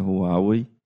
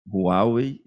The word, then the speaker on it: Huawei: E eu já começo esse podcast agradecendo que aprendi a pronúncia Huawei. Algumas vezes tem um fonemazinho de R no começo, Huawei, e a sílaba tônica está no meio.